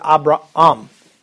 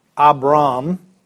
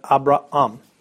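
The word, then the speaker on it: Abraham (Ἀβραάμ): And anytime you see two A's next to each other, you pronounce both of them. That's why I said Abraham, not Abram, but Abraham.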